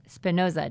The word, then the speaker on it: Spinoza: That this supports the statement of the Dutch philosopher Baruch Spinoza, did I say that right?